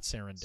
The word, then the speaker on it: Sarandos: Sarandos is his last name, not Sarandos. – Sarandos, yeah. – I threw in an extra syllable.